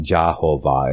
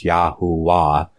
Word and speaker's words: Yahuwah: So, by his name Yah and his name Yahuwah, it's not Jehovah, it's Yahuwah, because the V was not pronounced.